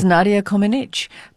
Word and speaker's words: Comăneci: Which apparently most of us have been pronouncing wrong for the past 50 years. – Uh-oh, tell me what is the right way. – Well, no, you did it correctly. – Oh. – It's Nadia Comăneci.